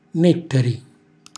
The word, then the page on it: nettari: calabrese